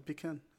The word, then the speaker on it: pecan: Yeah, it's a pecan. A pecan. – Pecan. – It's a pecan nut. – Pecan with a hard A.